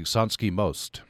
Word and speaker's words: Sanski Most: Uh, tell me about, uh, I hope I pronounced this correctly, Sanski Most. – Yes.